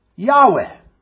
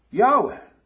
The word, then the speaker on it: Yahweh: And that name that he uses among his covenant people is Yahweh. I think that's probably the best pronunciation of it. Yahweh.